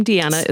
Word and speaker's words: Deeanna: So so my first name Deanna is spelled D E E A N N A, and my maiden name is Chappell C H A P P E L L.